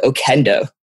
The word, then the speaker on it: Okendo: So then we actually just downloaded this really nice review app called Okendo. – What does it spell it? Spell it for people. – O-K-E-N-D-O.